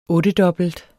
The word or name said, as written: ottedobbelt